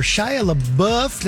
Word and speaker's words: Shia LaBeouf: Well, there's a little bit of beef going on in a Variety article where Shia LaBeouf, did I say that right? – Yes.